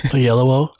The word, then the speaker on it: Oyelowo: Oyelowo.